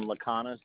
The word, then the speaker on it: Laconis: The letter G, last name Laconis,